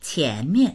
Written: qian2mian4

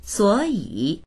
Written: suo3yi3

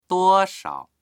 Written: duo1shao

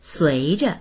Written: sui2zhe

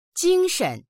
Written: jing1shen

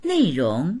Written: nei4rong2

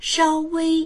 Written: shao1wei1

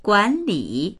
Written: guan3li3

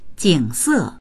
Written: jing3se4

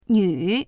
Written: nv3

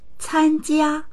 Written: can1jia1

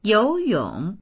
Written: you2yong3

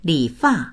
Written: li3fa4

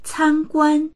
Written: can1guan1

